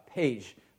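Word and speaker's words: Pécs: And in this little village called Pece, it's spelled P-E-C-S. I have no idea how you get Pece out of there, but if I end up going this summer, I'll let you know how the language works.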